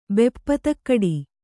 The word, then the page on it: ಬೆಪ್ಪತಕ್ಕಡಿ: kannada